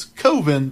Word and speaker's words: Coven: But we have to go back for him to make Coven, which he pronounces Coven because Coven sounds too much like oven.